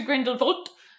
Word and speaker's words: Grindelwald: Yeah, it ranges from Grindelwald to Grindelwald. I kind of say Grindelwald, just so I don't sound pretentious, but I think that there's a more German pronunciation, potentially, that is more correct. We'll go with the W version.